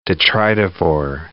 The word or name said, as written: detritivore